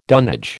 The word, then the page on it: dunnage: American English